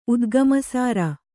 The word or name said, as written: ಉದ್ಗಮಸಾರ